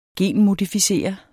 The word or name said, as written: genmodificere